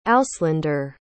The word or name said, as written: auslander